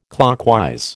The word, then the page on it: clockwise: American English